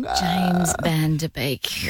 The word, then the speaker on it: James Van Der Beek: James Van Der Beek.